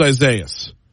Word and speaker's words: Isaias: Walk me through, before we get to anything else, Google tells me it's pronounced Isaias, but I've been hearing other pronunciations as well, pronunciations that add syllables that I don't think need to be added.